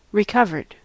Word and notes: recovered: American English

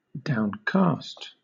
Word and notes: downcast: British English

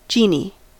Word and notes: genie: American English

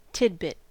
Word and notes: tidbit: American English

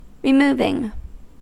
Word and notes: removing: American English

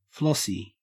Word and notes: flossy: Australian English

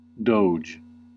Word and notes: doge: American English

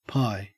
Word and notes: pie: Australian English

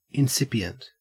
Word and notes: incipient: Australian English

